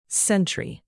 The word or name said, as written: sentry